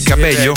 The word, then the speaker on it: Cabello: Di Cabella. – De chi? – Cabella. – Che è Cabella? Il mix tra Camila e Cabello? – Si legge così. – Veramente è Camila Cabello.